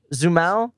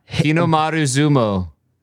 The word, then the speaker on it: Hinomaru Zumo: It's Hinomaru Zumo? Zumao? – Pretty close. Yeah. Hinomaru Zumo. – Is it O? The O-U sound? Does that make an O? – O. O. Always. Like every vowel is pronounced.